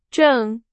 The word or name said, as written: 正